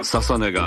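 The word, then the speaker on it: 楽音: 続いては現在 18 歳のシンガー、モデル、インフルエンサー、ササネが TOKIO HOT 100 初お目見え。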